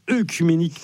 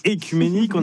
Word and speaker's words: œcuménique: Merci Clara, et nous sommes le 8 octobre et c'est donc l'anniversaire de l'ouverture du concile écuménique de Chalcédoine. Le 8 octobre 451, alors concile écuménique, ça peut sembler une expression un peu barbare. Déjà précisons qu'on ne dit pas œcuménique, mais écuménique.